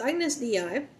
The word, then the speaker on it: Agnus Dei: Right, and entry number 43, Agnus Dei, that's how it's saying it's pronounced. So Agnus Dei,